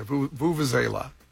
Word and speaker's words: vuvuzela: Yeah, Vuvuzela. – Kind of. – Yeah, that's how you say it.